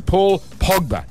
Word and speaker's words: Pogba: And days out from the start of the Premier League, Man United has nabbed Frenchman Paul Pogba. And I've pronounced that incorrectly. Paul Pogba. Thank you.